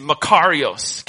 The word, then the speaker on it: makarios: The word Jesus used here is makarios.